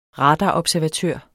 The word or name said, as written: radarobservatør